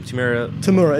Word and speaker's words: Temuera: Temuera. It's T-E-M-U-R-A.